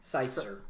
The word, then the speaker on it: Seitzer: Seitzer.